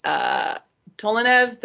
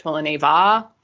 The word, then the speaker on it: Tolineva: So they could actually, speaking Russian and pointed out the thing with Tolinev, the female airman who's with them. Her last name should actually be pronounced Tolineva because she's a female and that's how Russian names work and stuff.